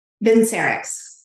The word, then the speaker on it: Vincerx: Vincerx.